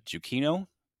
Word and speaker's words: Giacchino: And I was just sitting here getting ready, listening to or watching a podcast with the director, Matt Reeves, and the composer, Michael Giacchino, is how it's pronounced.